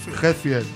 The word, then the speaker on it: Hetfield: O Hetfield, ¿cómo se dice esto? Que siempre... – Hetfield.